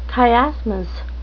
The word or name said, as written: chiasmus